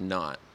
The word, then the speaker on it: Knaut: So in the ocean, there's Knaut, and it's spelled with a K, K-N-A-U-T. So it's like nautical combined, mm-hmm, with knots.